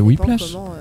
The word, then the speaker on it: Whiplash: Oui, c'est Whiplash.